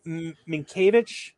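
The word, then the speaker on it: Mientkiewicz: Doug Minkiewicz. – Yep. – That's how you say his name. – That's how you say it.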